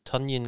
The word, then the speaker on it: Tonyan: Like, he pronounces his name Tonyan. Like, he said it's Tonyan like Funyan. That's how he said in the interview.